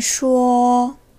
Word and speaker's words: 说: 쇼, 쇼, 말하다 라는 동사고요.